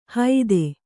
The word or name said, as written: ಹೈದೆ